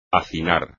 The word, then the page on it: hacinar: espagnol